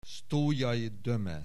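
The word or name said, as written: Sztójay Döme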